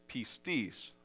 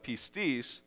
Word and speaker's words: pistis: And the Greek word is pistis, and I'm going to say it like that because when I say it the other way, it sounds like a swear word, and then everybody gets mad at me.